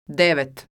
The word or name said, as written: devet